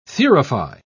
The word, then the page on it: thurify: British English